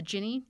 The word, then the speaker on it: Jinni: So the way it's written is the Jinni, J-I-N-N-I. But it's like a genie in a bottle.